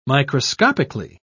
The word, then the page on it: microscopically: British English